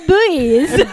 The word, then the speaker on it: Beuys: He studied at university in Freiburg, the academy in Karlsruhe, and studied with artist Joseph Buys. Is that right? Yeah. Buys? – Yeah. Buys. – It's spelled B-E-U-Y-S. – Yeah, it's Buys.